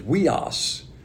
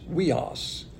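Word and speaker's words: weos (υἱός): The word son is weos. That's spelled U-I-W-O-S, weos.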